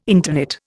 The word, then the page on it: interknit: Indian